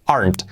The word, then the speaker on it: aren't: Que no sea aren't, no pronunciamos la e, aren't.